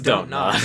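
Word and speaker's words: Dontnod: I'm going to assume it's Don't Nod, right? – Yeah. – Yeah. There's no punctuation there.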